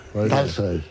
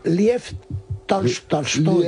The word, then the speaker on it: Tolstoy: Una A que tira hacia el O, pero que es A. – Una mezcla entre O y A. – O y A. – Tolstoy. – Porque las palabras que son, las palabras que se escriben en Tolstoy. – Las palabras que se escriben en O en ruso, pero van acentuadas, se pronuncian casi A.